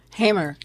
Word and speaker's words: Hamer: I mean, they basically threatened to blackmail Martin Luther King and Fannie Lou Hamer. I'm pronouncing it correctly? – Hamer, yes.